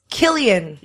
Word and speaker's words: Cillian: A lot of people wrote in, pronounce Killian. – Yes. – Murphy. Oh, yes. Killian.